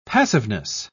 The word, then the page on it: passiveness: British English